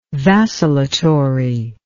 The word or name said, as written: vacillatory